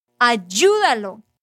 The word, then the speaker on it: Ayúdalo: Ayúdalo. Y lleva un acento en la segunda sílaba.